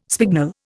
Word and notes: spignel: Indian

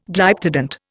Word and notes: Glyptodont: Indian

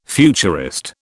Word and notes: futurist: British English

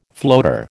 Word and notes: floater: American English

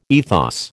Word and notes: ethos: American English

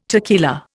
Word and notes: tequila: Indian

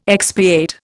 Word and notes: expiate: Indian

